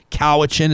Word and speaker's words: Cowichan: Your worship, first of all, have I correctly pronounced the name of the beautiful area where you serve the people as a public servant. You do say North Cowichan, is that correct? – Yes, we do, sir.